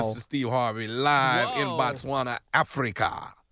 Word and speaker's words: Botswana: Botswana? Am I right? – Botswana, Africa. That's right.